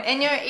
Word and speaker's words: Enjo: Enjo, E N J O, which is probably probably why I love it because the J is pronounced yeah, just like my name.